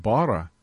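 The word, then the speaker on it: bara (ברא): Bet resh aleph, pronounced bara, is the Hebrew word for created.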